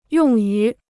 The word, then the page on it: 用于: yòng yú